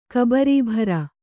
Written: ಕಬರೀಭಾರ

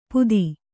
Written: ಪುದಿ

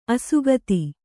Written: ಅಸಕ್ತ

